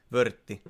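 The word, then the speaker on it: Würth: Miten tää lausutaan? Vurtti vai Vyrtti? Vörtti.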